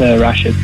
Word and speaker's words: Rashid: Hey, it's Rashid, but okay.